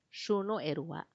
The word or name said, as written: xunuerua